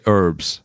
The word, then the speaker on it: herb: Well, I can't remember which one is right in terms of herb or herb, because I think it's... – Americans say herbs. – Right. They just make it a silent H.